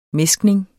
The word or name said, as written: mæskning